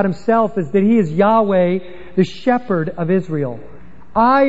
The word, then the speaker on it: Yahweh: Yahweh, Jehovah. Yahweh is the Hebrew pronunciation, Jehovah the anglicized.